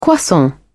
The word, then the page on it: croissant: British English